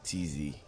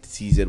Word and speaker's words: Tzy: And so my dad always calls me Tzy, like a pet name. So I decided to spell it Tzy because nobody had it then.